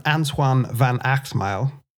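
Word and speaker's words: Antoine van Agtmael: So in 1981, Antoine van Achtmael, that is spot on pronunciation,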